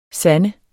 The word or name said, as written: sande